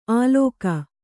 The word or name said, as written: ಆಲೋಕ